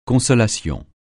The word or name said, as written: consolation